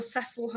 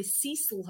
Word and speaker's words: Cecil: Do you know about the Cecil Hotel or the Americans say Cecil Hotel in LA?